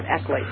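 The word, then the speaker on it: ICLEI: I think ICLI is more better than ICELI. I just keep forgetting how to pronounce it. It is ICLI.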